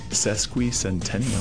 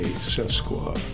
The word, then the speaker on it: sesquicentennial: Sesquicentennial. – Sesquicentennial. We'll be right back. Sesquicentennial. – I thought it was an A, sesqua. – Sesquicentennial. – Not like Sasquatch.